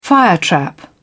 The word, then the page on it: firetrap: British English